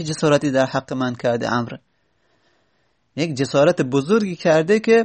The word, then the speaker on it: عمرو: میگه میگه عمر میبینی که وقتی که مینویسن عمر، یک وو کنارش میذارن، یعنی عمرو، یعنی یک وو میذارن دیگه. خب این که مستحقش نبوده عمر یک وو بگیره. عمر عین میم راه هست، وو که نداره تلفظ نمیزنیم.